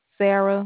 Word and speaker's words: Serra: Sarah, which is spelled S E R R A. – Yes. E-R-R-I-A. – No, it's just S-E-R-R-A. – Okay, S-E-R-R-A.